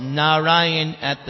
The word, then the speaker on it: Narayana: Because Ajamila chanted this name of Narayan at the time of death, and he was saved from going to hell. He was about to go to hell, but because he said those four syllables, Narayana.